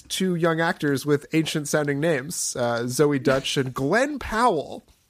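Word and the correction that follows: Deutch: Deutsch. – Is that how it's said? – That's right.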